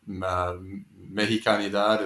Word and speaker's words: Mexicanidad: So I wonder if you can talk a little bit about, you know, Jose Nápoles and Mexicanidad. Is that how you pronounce that yes. – word? – Yes, that's a very good pronunciation. Yes. – You're very generous. – No, it was. It was spot on.